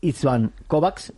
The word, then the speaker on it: István Kovács: Iker Zamayoa, que ya mañana hablaremos con Ivon Uriza del colegiado, el rumano István Kovács, o como se diga, ¿cómo se pronuncia? – Sí, sí, yo creo que lo has pronunciado perfectamente.